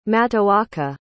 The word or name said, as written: matoaka